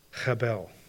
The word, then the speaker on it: hebel: It would have been pronounced when Solomon wrote these words as a word that sounds like breathlessness, hebel, breath.